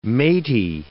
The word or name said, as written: matey